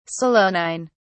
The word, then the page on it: solanine: British English